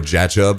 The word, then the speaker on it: Jachob: Mine's Jacob, but my dad decided to spell it J-A-C-H-O-B. For some, yeah, or Jachub, I like to call it.